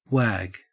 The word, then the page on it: wag: Australian English